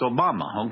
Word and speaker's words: Obama: It's like Osama, but it's Obama.